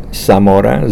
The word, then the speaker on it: Zamora: Emilio, E-M-I-L-I-O, Zamora, Z-A-M-O-R-A. Sometimes I go by Emilio Solis Zamora. Solis is my mother's name, S-O-L-I-S.